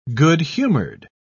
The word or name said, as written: good-humored